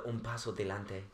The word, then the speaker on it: un paso adelante: After un paso adelante. Did I say it right? Yeah, it was Perfect.